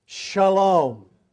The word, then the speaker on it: Shalom: Now, in the Hebrew tongue, the word Salem is pronounced Shalom.